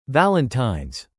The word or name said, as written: Valentines